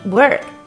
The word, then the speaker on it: work: 工作读作 work，而走路读作 walk，区别就在于 work 的卷舌音。